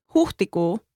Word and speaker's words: huhtikuu: For example, we see it in the Finnish word huhtikuu, meaning April.